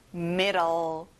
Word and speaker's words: middle: 중간, 가운데, middle. 자, D 발음을 보통 르, L 발음으로 해보세요. Middle. That's right.